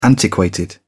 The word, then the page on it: antiquated: British English